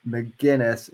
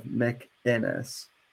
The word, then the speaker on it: McInnes: Uh, he DMCA'd a video in which I was actually agreeing with Gavin on, but he DMCA'd me because I mispronounced his name McGinnis instead of McInnes.